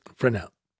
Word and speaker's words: Fresnel: Fresnel. – Silent S. – Fresnel.